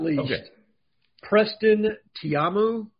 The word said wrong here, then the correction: Taumua: Is that how you say it, Cole? – I've been saying Taumua. – Taumua, you're probably right.